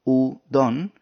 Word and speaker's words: udon: Lo mismo pasa en el caso de la palabra udon, donde en vez de contar 2 sílabas, udon, se deberían contar 3.